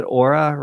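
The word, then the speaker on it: Oura: So you recently wrote about I believe you pronounce it Aura ring. Is that? – Yes, which I'm still wearing.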